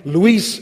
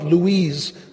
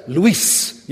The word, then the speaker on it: Luis: Luis is a man's name, okay? Not Louise. That's a girl, and I'm a man. I'm all for women, but I'm a man. And if you want to pronounce it right, there's like triple S. Luis, you know, that will help you.